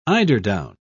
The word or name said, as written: eiderdown